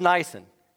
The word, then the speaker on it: Nisan: But the second thing I want to point out is I did not mispronounce the name of the month. It is Nisan. It is not a Japanese car.